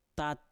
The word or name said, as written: tat